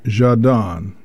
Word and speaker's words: Zhadan: So let's go now to the tenor Yvan Jadan. We transliterate that in English as Z-H-A-D-A-N.